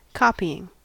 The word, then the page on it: copying: American English